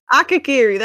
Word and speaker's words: Akakiri: Akakiri? – Yes. – Akakiri, there we go.